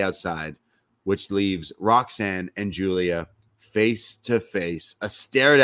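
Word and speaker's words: Giulia: It's G-I-U-L-I-A. It's the Julia. – Italian.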